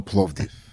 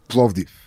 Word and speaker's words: Plovdiv: Because the mayor of Bulgaria is going to give me the key of my hometown, Plovdiv. – What's it called? – Plovdiv. – Plovdiv.